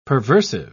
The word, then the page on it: perversive: British English